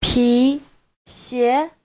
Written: pi2xie2

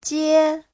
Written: jie1

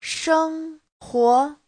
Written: sheng1huo2

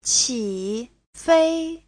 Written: qi3fei1

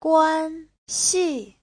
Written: guan1xi4

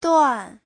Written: duan4

